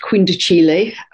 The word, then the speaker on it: quindecile: It's spelt quindecile, but it's pronounced quindicile.